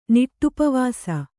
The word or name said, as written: ನಿಟ್ಟುಪವಾಸ